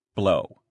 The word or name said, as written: blow